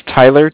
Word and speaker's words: Tyler: My name is Tyler, T-Y-L-E-R. – Okay. – I'm calling with Cardiff. It's spelled C-A-R-D-I-F-F, as in Frank.